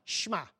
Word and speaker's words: Shema: In Hebrew, the equivalent word to hear or listen, aku, the equivalent word is shma. Can we all say that word? Shema, Shema. And it's spelled S-H-E-M-A, Shema. Okay, that's how you pronounce it.